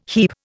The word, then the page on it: heap: Indian